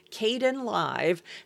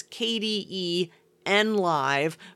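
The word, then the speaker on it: Kdenlive: Now, in my head, I still pronounce it as KDE NLive, but I'm training myself to call it Kden Live now that I've been hearing people talk about it.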